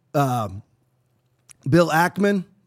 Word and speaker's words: Ackman: Why was it just very, again, it's just all coincidental, I guess, that there was this struggle session in the Hamptons with Bill Ackman.